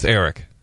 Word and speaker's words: Eirik: E-I-R-I-K. – That's Eric.